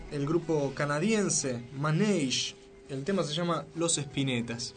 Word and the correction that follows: Les Epinettes: O casi. El tema se llama Les Epinettes, que contrariamente a lo que uno podría pensar a primera vista, no, se escribe Les Epinettes, así que la similitud es profusa.